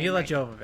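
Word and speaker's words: Milla Jovovich: Mila Jovovich.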